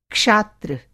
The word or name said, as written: क्षात्र